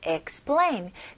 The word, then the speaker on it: explain: explain, explain, explain. 그래서 E로 시작하지만 explain이라고 발음하는 것보다는 I 발음 느낌으로 원어민들이 정말 많이 발음합니다. 그래서 explain 이거보다는 explain, explain. 아마 사전 보시면 두 가지 발음이 나올 겁니다.